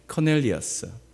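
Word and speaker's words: Cornelius: 사도행전 10장 1절에 그분의 이름이 나오는데 그 이름이 고넬료라고 합니다. 영어식으로 발음하면 커넬리어스.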